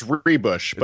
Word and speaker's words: Ruebusch: It's Ruebusch, but it's okay because, Ruebusch makes no sense given the spelling, so I don't really ever get upset about it. – The U is silent, right? – Yeah, pretty much. I think it's silent, or it pretends that it's the other order of letters or something.